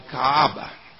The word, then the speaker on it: Kaaba: The people of Mecca at this time had a religion called Kaaba. Now, that's hard to pronounce. It's K-A-A-B-A.